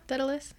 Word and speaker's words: Daedalus: I'm probably saying his name wrong, but Dad. – No, Daedalus. Yeah. Daedalus.